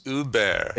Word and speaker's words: Hubert: And if you're really into French, the H is silent. So it's Hubert.